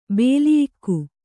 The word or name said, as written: ಬಲಿಯಿಕ್ಕು